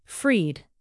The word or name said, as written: freed